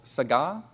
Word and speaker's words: saga: Instead of gadol, it's saga. That's spelled S-A-G-A, like saga.